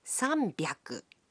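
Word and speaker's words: sanbyaku (さんびゃく): But the pronunciation can be a bit tricky. 300 is pronounced sanbyaku. 600 becomes ropyaku.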